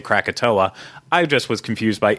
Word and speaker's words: Krakatoa: That is because it was spelled Krakatau, which I assumed that was going to be Krakatau because that's how I would phonetically say it out. Someone pointed out to me in the comments that that's actually the Indonesian way of spelling Krakatoa, which, of course, I know Krakatoa.